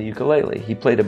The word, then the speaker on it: ukulele: And the Hawaiian, the indigenous name of it is ukulele. And so I know enough from singing Hawaiian songs that if you see a U, it is pronounced oo.